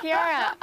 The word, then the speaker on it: Chiara: Yes, Blessed Chiara.